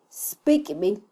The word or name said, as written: spikybil